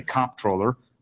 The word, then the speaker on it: comptroller: I happen to say comptroller. I hit it hard on the first syllable.